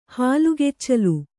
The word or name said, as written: ಹಾಲುಗೆಚ್ಚಲು